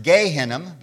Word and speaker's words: Gehinnom: Valley Hinnom in Hebrew is pronounced Gehinnom, because Ge means valley, which is translated into Greek as Gehenna, the word we find used in the New Testament.